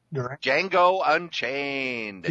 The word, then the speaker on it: Django Unchained: Django Unchained.